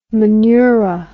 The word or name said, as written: menura